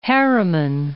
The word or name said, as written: Harriman